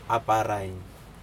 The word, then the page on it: aparaiN: Aneityum